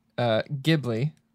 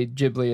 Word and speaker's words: Ghibli: So it is pronounced Ghibli. – Oh, fuck okay. – No. oh, fuck no. – But a number of people in Japan say Ghibli as well, and no one is corrected.